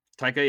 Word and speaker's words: Taika: Takeda does his voice, right? – Taika, yeah.